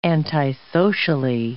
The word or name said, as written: antisocially